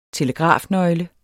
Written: telegrafnøgle

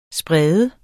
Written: sprede

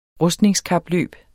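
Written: rustningskapløb

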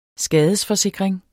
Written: skadesforsikring

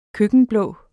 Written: køkkenblå

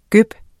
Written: gøb

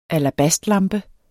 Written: alabastlampe

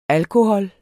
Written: alkohol

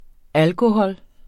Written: alkohol

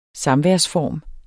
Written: samværsform

